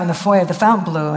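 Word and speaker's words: Fontainebleau: And I went by myself, and I went and I stayed in the Fountain Blue, which is how they say it.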